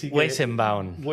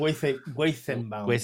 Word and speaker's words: Weizenbaum: Hostia, Weizenbaum. – Weizenbaum.